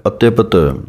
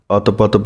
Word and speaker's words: अत्यपत: लाओ लई तृष्णा इत्थे जमक है, पाठ है अत्यपत। हुण इस पाठ नूं बहुते अतपत पढ़ जांदे हन, लेकिन सही उच्चारण है अत्यपत।